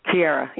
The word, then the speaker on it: Chiari: Chiari, yes. – Oh, Chiari. I'm sorry. – Chiari. I said it wrong.